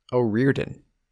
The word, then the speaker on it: O'Riordan: The second O is silent. O'Riordan is how they were pronouncing it over there.